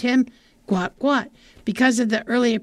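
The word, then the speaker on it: Guot: In 2005, Bona and Monica Gout, pronounced Guat, sought to leave war-ravaged South Sudan.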